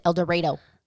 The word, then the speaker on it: El Dorado: which one of the towns is called El Dorado, but they pronounce it El Dorado.